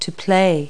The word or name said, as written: play